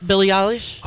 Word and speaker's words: Billie Eilish: They have a lot of like guest stars in there, including Billie Eilish. Is that how you say her name? – Yep, Billie Eilish.